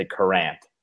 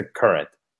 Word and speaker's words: Courant: You're going to say Courant. It's the Current.